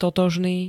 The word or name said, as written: totožný